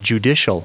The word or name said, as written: judicial